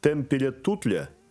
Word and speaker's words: Темпеля-Туттля: Как я почитал про комету Темпеля-Туттля, правильно произношу? – Да.